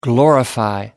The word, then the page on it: glorify: American English